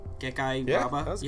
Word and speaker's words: Gekki Yaba: Is that Gekki Yaba? – Yeah, that was good.